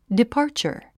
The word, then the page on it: departure: dipάː r tʃə r; ディパーチャァ